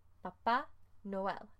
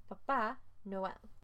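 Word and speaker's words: Papá Noel: Papa Noel. And you have to be careful how you say it, because if you say Papa, then it's the Pope. But if you say Papa, then it's Santa Claus.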